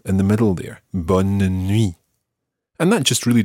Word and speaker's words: bonne: However, when we come to say good night, we use a slightly different version of the word for good, and that is bonne. – Bonne. – And that's spelled B-O-N-N-E, as opposed to bon, which is spelled B-O-N. Listen to the difference between these two words. Bon, bonne.